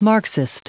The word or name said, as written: marxist